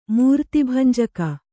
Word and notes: ಮೂರ್ತಿಭಂಜಕಿ: mūrti bhanjaki

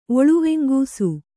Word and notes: ಒಳುವೆಂಗೂಸು: Kannada

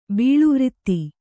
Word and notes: ಬೀಳುವ್ರಿತ್ತಿ: kannada

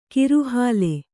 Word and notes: ಕಿರುಹಾಲೆ: kiru hāle

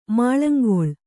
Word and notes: ಮಾಳಂಗೊಳ್: Kannada

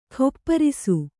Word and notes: ಗೋಪರಸ: kannada

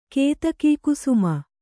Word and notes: ಕೇತಕೀಕುಸುಮ: Kannada

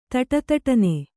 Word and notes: ದುಡುದುಡನೆ: Kannada

